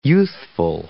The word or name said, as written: youthful